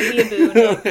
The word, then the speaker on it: weeaboo: A weebo? – I was a weeaboo. – A weeaboo, I'm sorry. – It's pronounced weeaboo.